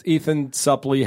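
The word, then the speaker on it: Ethan Suplee: Ethan Suppley has. – I'm glad you say his name right.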